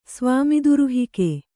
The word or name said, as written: ಸ್ವಾಮಿದುರುಹಿಕೆ